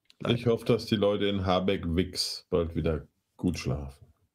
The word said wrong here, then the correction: Havixbeck: Havixbeck, ja. – Oh, Havixbeck? Oh, sorry, habe ich es falsch gesagt.